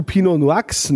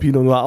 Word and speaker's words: Pinot Noir: Der Spätburgunder Pinot Noir von Daniel Twardowski oder sagst du Pinot Noix? Nee, ne? das wird ja schon Pinot Noir ausgesprochen.